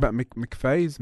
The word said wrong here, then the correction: McAfee: Excuse the mispronunciation. – Yeah, it's McAfee.